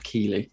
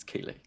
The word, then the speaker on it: Keighley: Um, mm-hmm. Keithley. Yeah, I mean, it's, look, pronounced, been... it's spelt Keithley, like Keithley Market up north. I will refer to him as Keithley. Jeff Keithley. – It's Keighley.